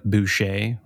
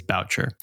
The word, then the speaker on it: Boucher: It's spelled like Boucher, like Bobby Boucher, but I believe it is Boucher.